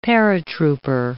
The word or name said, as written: paratrooper